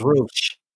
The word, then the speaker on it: Roche: I thought it was Roche. – Roche, that's what it is. – Sorry, I think it is Roche. It's spelled Roche. So probably Roche.